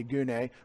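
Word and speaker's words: gune (γυνή): Wives, or gunaikos, also gune is the dictionary form, G-U-N-E, long E, gune.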